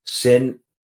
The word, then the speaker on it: synesthesia: But you take concepts like entropy and, like, I can't even pronounce this one, synesthesia.